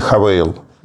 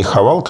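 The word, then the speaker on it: Haval: И Хавейл. Не Хавал, как произносят а как многие.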